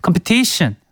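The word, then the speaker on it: competition: 컴피티션도 컴패티션, 한국어로는 컴패티션이지만 컴피티션 이렇게 한다라는 거 우리 성경님께 말씀드려봅니다.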